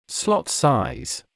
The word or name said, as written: slot size